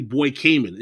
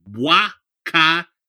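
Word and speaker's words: Bois Caïman: to explain about this phenomenon of, now people don't know how to pronounce it because if you spell it in English, it sounds like Ceremony Boy Cayman. It's Ceremony Bois Cayman. Ceremony Bois, it's spelled B-O-I-S-C-A-Y-E-M-A-N.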